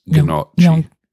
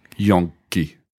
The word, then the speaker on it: gnocchi: I don't actually know how you pronounce gnocchi. – Gnocchi. – But there's no N before the K. Yonki. – I know, but I'm just saying I had an Italian roommate. And that's what she told me how to say it.